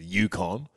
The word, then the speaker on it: Yukon: It's the Yukon.